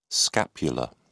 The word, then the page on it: scapular: British English